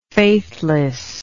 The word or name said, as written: faithlessness